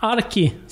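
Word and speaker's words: Archie: Arki som det uttalas på svenska.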